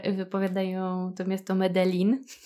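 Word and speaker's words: Medellín: Antioki, czyli regionu, w którym, którego stolicą jest Medellin, powiedzą właśnie Medellin. tak jakby dżin wyskakiwał z lampy.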